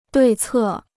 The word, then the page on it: 对策: duì cè